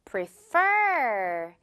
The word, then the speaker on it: prefer: 그래서 A와 B 중에 뭔가를 더욱더 좋아하다라는 뜻이고 자 발음은 뒷부분에 지금 F 발음 있고요. 그 부분에 강세도 주면서 갈게요. Prefer. Pre 앞부분은 지금 한글 우리말의 그 P과 비슷한 P 발음으로 시작하지만 뒷부분은 지금 이 토끼 이빨 바람 발음이죠. 두 개 구분하면서 갈게요. Prefer 아닌 prefer, okay?